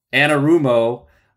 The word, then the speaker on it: Anarumo: And their defensive coordinator, Lou Anarumo, how do you say his last name again? – Anarumo.